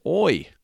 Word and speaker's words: hoy: Now, today's word of the day, or la palabra del día de hoy, is hoy. And hoy means today, so hoy is spelled H-O-Y, hoy. Remember, the H is always silent.